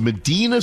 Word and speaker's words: Medina: Medina Spirit, it's spelled like our East Side community, Medina, but it's Medina Spirit.